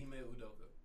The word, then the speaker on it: Ime Udoka: Ime Udoka. – I can't pronounce that. – Nigga. Celtic nigga. – Wow. – With the coach. There you go. – All right, one more time. Ime.